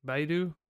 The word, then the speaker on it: Baidu: It's Baidu. – Baidu.